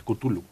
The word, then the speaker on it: Cthulhu: El Cthulhu, los, mitos de Cthulhu, que nunca supe pronunciarlo bien, pero creo que se dice Cthulhu.